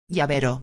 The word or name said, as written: llavero